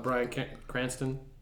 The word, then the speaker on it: Bryan Cranston: I love the Mountain Dew one with Bryan Cranston.